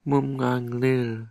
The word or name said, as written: mumnganglil